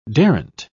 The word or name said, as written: daren't